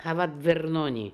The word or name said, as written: Havatvernoni